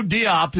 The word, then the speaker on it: Diop: I know Diop is how you say his name.